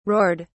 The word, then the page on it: roared: British English